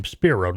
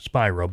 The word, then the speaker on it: Spiro: But I think he pronounced his name Spiro, not Spiro.